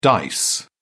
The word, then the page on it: dice: British English